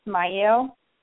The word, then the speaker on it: Mielle: Okay, so first, I know a lot of people don't know how to pronounce it, so it's Mielle Organics, and it's a combination of my kids' names.